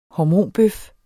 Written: hormonbøf

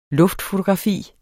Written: luftfotografi

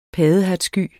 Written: paddehattesky

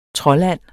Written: troldand

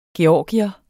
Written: georgier

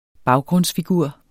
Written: baggrundsfigur

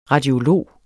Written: radiolog

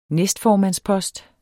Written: næstformandspost

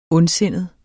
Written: ondsindet

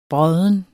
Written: brodden

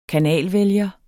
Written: kanalvælger

